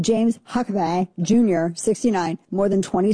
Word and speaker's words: Huckabay: Yeah, so the guy's name is Huckabay. Now, I'm not saying it like Britney Spears. It's spelled like Huck, A-B-A-Y. So not like Huckabee. It's Huckabay, H-U-C-K-A-B-A-Y. See the difference?